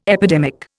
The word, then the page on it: epidemic: Indian